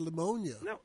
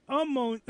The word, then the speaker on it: pneumonia: He died, he had pneumonia. – No, he didn't have pneumonia. He had pneumonia. You got me saying it wrong, too. Pneumonia.